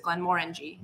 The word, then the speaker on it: Glenmorangie: I learned it the wrong way. Which is Glenmorangie, but it's Glenmorangie. – Morangie, yeah. Like you're saying the word orange.